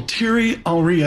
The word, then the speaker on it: Thierry Henry: She tells me that also besides Mbappe going to the Federer on shoe thing, that one of my favorite people, Thierry Henry, I hope I'm pronouncing that correct. – You did.